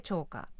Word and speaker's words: 長歌: 長い歌と書いて長歌と読みます。